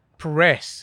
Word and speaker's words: Perez: Perez.